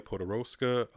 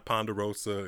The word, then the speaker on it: Podoroska: Another player I wanted to mention that me and Brian didn't get a chance to talk about was Nadia Podoroska. A lot of people have been butchering her last name. To me, it's not that hard. It's not Ponderosa. It's not pork chop. It's not none of that stuff.